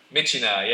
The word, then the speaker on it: Myitkyina: Myitkyina? – Myitkyina, yeah, Myitkyina, which is.